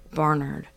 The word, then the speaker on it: Barnard: I heard you're supposed to say it, like, with no syllables. Like, if you say it, like, really, like, quietly almost, like, that's the way to say it. – Bernard.